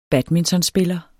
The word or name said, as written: badmintonspiller